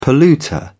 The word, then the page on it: polluter: British English